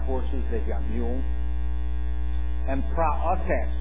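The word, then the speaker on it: Praotes (πραότης): It is the Greek word praotes, or some say people say praotes, the praotes. Okay? Praotes, as she said. The right way to say praotes, okay?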